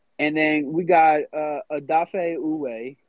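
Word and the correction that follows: Odafe Oweh: Ooh, I hope I said that right. – It's Odafe Owa.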